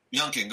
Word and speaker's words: Youngkin: The guy, Youngkin, how you say his name? – Youngkin, go ahead.